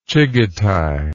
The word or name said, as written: chigetai